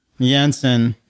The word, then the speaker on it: Jensen: Jensen. – Yeah. You gotta say it like my mother when she's mad at me. – Jensen.